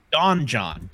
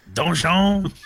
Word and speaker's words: donjon: Oh, you you've never gone to the website donjon? John John? donjon. D O N. It's French for dungeon. Oh, oh, oh, oh. oh, oh. oh sorry, I mispronounced it. donjon.